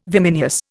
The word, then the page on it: vimineous: Indian